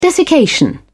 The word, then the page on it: desiccation: British English